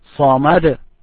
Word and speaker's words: Samad: Samad. Ad. Samad. Allahu Samad. – Allahu Samad. – Vibrate the last letter.